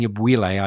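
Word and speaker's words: Anyabwile: I think, and I found this really helpful, there's a fascinating discussion between Tabiti and Yabwile. I don't know if I'm pronouncing that correctly. It's pretty good. Yeah, I know Tabiti, and that's pretty good.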